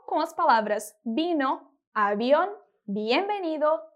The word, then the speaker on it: bienvenido: A pronúncia dela é bienvenido. Mais uma vez, bienvenido.